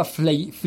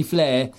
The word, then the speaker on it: Flea: Lui si identifica, o comunque ha passione per Flea, che in realtà si pronuncia Flea, quindi Flea, dei Red Hot Chili Peppers.